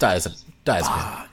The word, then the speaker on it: diazepam: But anyway, she drugs him with a drug called diazepam. I think that's how you pronounce it. Is that how you pronounce it? Yeah, it's diazepam. Yeah.